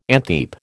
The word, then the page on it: antheap: American English